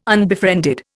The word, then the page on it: unbefriended: Indian